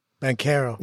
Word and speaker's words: Banchero: Banchero.